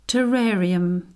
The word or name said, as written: terrarium